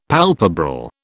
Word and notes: palpebral: British English

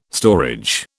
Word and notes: storage: British English

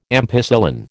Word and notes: ampicillin: American English

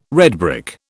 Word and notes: redbrick: British English

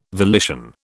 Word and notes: volition: British English

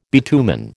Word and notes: bitumen: American English

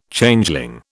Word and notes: Changeling: British English

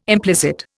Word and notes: implicit: Indian

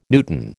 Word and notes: newton: American English

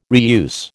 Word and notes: reuse: American English

